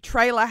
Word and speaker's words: Traylor: I think Trailer Howard. – Trailer? – Yeah, trailer. According to Wikipedia, you spell it T-R-A-Y-L-O-R.